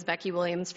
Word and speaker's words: Beckie Williams: My name is Becky Williams from Lewistown on behalf of American Prairie. And again, my name is spelled B-E-C-K-I-E-W-I-L-L-I-A-M-S.